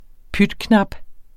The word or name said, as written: pytknap